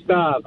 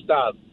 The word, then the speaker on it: Stav: Hi, this is actually Stav. How are you, Dennis? – You're kidding me, right? – No, my name is Stav. – Spell it. – S-T-A-V, as in Victor.